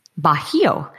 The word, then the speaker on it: Bajío: So how long of a downtime was there between Sims and, now is it Baggio? Am I saying it right? – Bajío.